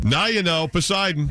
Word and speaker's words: Poseidon: Hey, is it Posey Don? – Say again? – What'd you say? I don't know if I'm saying it right. Posey Don? – Poseidon? – Oh, Poseidon Adventure.